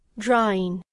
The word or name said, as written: drawing